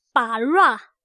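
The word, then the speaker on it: “bara”， (ברא): 希伯来文的发音叫做 “bara”， “bara”， “bara”。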